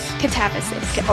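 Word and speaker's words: Katabasis: I say it right? Catabasis? – Catabasis. – Okay. Okay, Catabasis.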